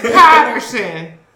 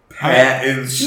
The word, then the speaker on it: Pattinson: He's saying it wrong. It's Patterson. – Pattinson.